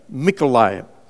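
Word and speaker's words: Mykolaiv (Миколаїв): In Ukrainian language, it comes out pronounced as with an M sound, Mykolaiv.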